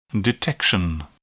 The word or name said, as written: detection